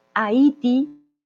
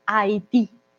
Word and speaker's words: Haití: Haití para nosotros Haití, esta pronunciación no existe, a pesar de que está la H, no la pronunciamos, recuerden la H muda para nosotros, Haití. Hagan de cuenta que la H no existe, la H no está ahí, y la tilde muy importante, Haití.